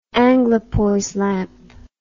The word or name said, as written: anglepoise lamp